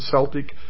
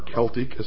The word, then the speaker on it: Celtic: Examples are ancient Mexican, Mayan, Inca, Aztec, Sumerian, Babylonian, Assyrian, Egyptian, Celtic, or Celtic as some pronounce it, Aryan, etc.